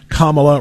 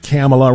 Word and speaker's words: Kamala: The approval ratings for the vice president, Kamala, rhymes with communist, not Kamala, rhymes with capitalist, Harris, even lower than that.